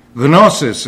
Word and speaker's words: gnosis: Gnosis is the way it's pronounced in Greek. Notice the G is in there.